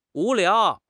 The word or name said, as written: wu2 liao2